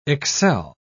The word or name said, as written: excel